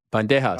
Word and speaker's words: Bandejas: Had a freakish fight against Ricky Banderas. – Bandejas. – No, I'm saying that wrong. Bandejas.